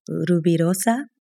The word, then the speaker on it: Rubirosa: And I want to go back to just the, I took Spanish, but I might say this incorrectly, Rubirosa. – Uh-huh. Beautiful.